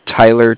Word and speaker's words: Tyler: My name is Tyler, T-Y-L-E-R, and I'm calling with Cardiff. It's spelled C-A-R-D-I-F-F, as in Frank, Frank.